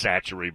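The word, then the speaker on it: Chachere: It's spelt funky. It doesn't look like it's Satchery, but that's what you're referring to there.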